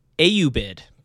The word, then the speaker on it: Ayyubid: God, this is a tough word. Ayyubid. That's A-Y-Y-U-B-I-D. The Ayyubid.